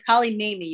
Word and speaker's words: Mamiye: It's close. Holly Mamiye, you can say.